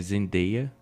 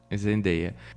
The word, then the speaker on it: Zendaya: É Zendaya. Zendaya.